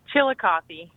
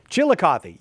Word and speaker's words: Chillicothe: It's Chillicothe. – Chillicothe. Yeah, that's what I was going to say. – Chillicothe, yep.